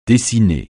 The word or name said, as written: dessiner